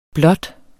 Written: blot

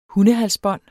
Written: hundehalsbånd

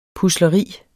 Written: PUSLERI

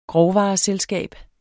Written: grovvareselskab

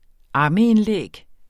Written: ammeindlæg

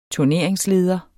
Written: turneringsleder

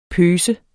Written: pøse

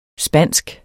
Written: spansk

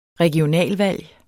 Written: regionalvalg

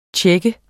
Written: tjekke

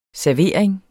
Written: servering

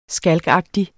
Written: skalkagtig

